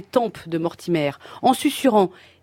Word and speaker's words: Mortimer: Que je sache, Mortimer, ça rime avec lover.